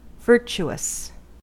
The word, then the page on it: virtuous: American English